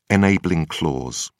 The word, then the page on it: enabling clause: British English